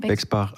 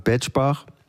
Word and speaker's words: Bexbach: Ja, wird es auch so ausgesprochen? – Bexbach. Also die Bexbacher sagen Betschbach.